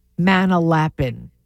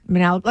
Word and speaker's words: Manalapan: anybody who's never been to New Jersey says Manalapan. And I don't know why. Instead of Manalapan. Like, we make fun of it. And we're like, Manalapan.